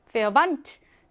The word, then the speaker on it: Verwandt: Verwandt. 내 태는요, 여러분, 드트 이렇게 발음하지 않고요. 합쳐가지고 트한 번만 발음해요.